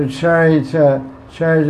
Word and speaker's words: Charita: So Charita means character. It almost sounds like character. Charita, character. Very similar. Because C-A and C-A in Sanskrit is pronounced C-H. Cha. So, cha for charita and character. How do you spell character? – C-H-A-R-A-C-T-A.